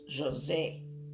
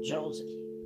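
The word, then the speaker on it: Josée: Well, my native tongue is French. So my name in French is José. Here they say Josie, but it's spelled the same way, two E's.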